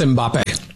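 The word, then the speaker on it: Mbappé: That's Mbappe.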